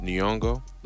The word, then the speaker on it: Nyong'o: I think her last name is pronounced Nyong'o. Lupita Nyong'o.